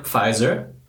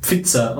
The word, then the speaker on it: Pfizer: Deshalb habe ich es auch immer am Anfang ausgesprochen, Pfizer und nicht Pfizer, weil für mich war es immer ein Pfizer.